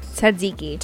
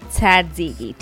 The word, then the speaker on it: tzatziki: How do you pronounce it? – Tzatziki. – Tzatziki? – Tzatziki. T-Z-A-Z-I-K-I.